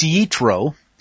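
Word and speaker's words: Yitro (יתרו): Jethro, or now it's NCIS that everybody sees, but in the Hebrew it's Yitro, and it's been pointed out it's spelled more than one way.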